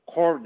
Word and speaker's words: corps: İşte wide receiver e, core derler. Corps yazılır da core okunuyor.